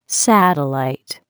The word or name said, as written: satellite